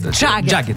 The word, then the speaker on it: Jagged: Jacket. Я у Димы всегда переспрашиваю, правильно ли я произношу это? – Нет, здесь ты можешь... Это как, ну, как Мик Джаггер. – Вот, например, то есть Jagged.